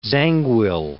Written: Zangwill

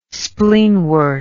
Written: spleenwort

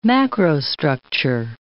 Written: macrostructure

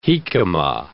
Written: jicama

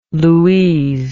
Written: louise

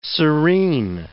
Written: serene

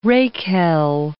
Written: rakehell

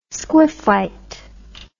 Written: squiffite

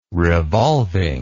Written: revolving

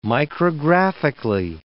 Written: micrographically

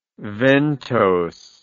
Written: ventose

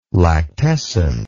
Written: lactescent

